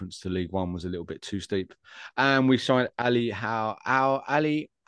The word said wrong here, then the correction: Ali Al-Hamadi: I think that's how you pronounce it. The Iraqi international. – Ali Al-Hamadi.